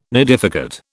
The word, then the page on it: nidificate: British English